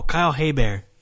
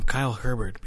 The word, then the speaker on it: Hebert: Oh, Kyle Hebert. I was calling him Kyle Herbert before I knew his name.